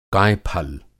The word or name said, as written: कायफल